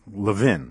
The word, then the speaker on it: Levin: It's Levin, right? Yuval yes. Levin.